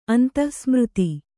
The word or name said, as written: ಅಂತಃಸ್ಮೃತಿ